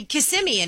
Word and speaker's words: Kissimmee: Yeah, she's the one who's confused me, and I don't know how to say Kissimmee anymore. – Yeah, yeah. – I think... Did I just say it right? – You said it right.